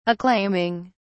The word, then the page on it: acclaiming: British English